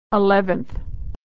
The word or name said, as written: eleventh